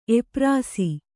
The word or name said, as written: ಎಪ್ರಾಸಿ